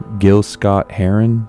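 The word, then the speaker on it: Gil Scott-Heron: One thing that I brought today that I'm actually very excited for is, Gil Scott Heron, is that how you pronounce mm-hmm.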